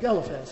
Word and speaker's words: Eliphaz: Do you call him Eliphaz out here? What do people use? Eliphaz? Is that good enough? Eliphaz. That's the right way to pronounce it, supposedly.